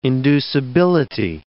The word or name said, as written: inducibility